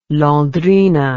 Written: Londrina

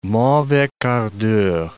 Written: mauvais quart d'heure

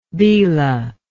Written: belah

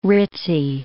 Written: ritzy

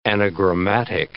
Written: anagrammatic

